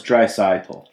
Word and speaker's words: Draisaitl: So it's Draisaitl.